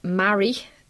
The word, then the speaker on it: Marie: Marie. – It's pronounced Mary, as in I'm married to Damien.